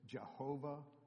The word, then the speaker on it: Jehovah: In German, the Y is pronounced as a J. The W is pronounced as a V. So Germans would pronounce it Jehovah.